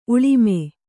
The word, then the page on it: ಉಳ್ಮೆ: kannada